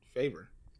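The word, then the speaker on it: Favour: And I hate that like my name has a U in it or whatever. And for some reason that just like trips people up. It's like, so like, how do I pronounce it? It's like, oh, it's favor.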